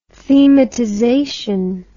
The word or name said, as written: thematization